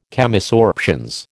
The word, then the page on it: Chemisorptions: American English